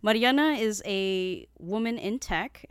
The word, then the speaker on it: Mariana: It's going to be Callie and Mariella. Ooh, am I saying that right? – Mariana.